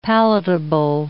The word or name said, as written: palatable